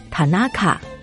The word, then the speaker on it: 田中: 以田中这个姓氏为例，日语的发音是 Tanaka，而汉字的发音是田中。